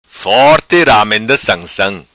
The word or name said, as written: Sansam Forti Ramenda